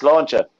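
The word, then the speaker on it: Sláinte: Does that say Slauncher? – Yes, Slauncher.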